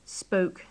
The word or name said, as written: spoke